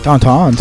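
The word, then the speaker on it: Tintin: La ficción de Jergé para lo que fue Tantan llegó al punto de prácticamente llegar a ser como de Julio Verne, güey. – Tantan. – Sí. Tantan. Vamos a presumir que sabemos pronunciar Tantan.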